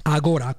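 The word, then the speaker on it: Agorà: agora con l'accento sulla A, sulla A.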